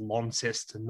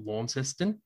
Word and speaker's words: Launceston: It's actually pronounced Launceston, not Launceston.